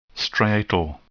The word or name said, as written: striatal